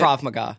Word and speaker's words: Krav Maga: Krav Maga.